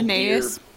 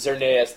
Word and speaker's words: Xerneas: X was the, I can't even pronounce the name of that mystical Xerneas. – Deer. Xerneas, there you go, and, Yveltal from Y.